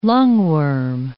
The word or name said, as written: lungworm